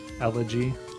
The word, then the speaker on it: Elegy: How do you pronounce that? – Algae? – Elegy. – A-L-G-A-E? – E-L-E-G-Y. – Oh, Elegy.